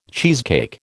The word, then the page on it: cheesecake: American English